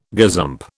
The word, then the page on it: gazump: British English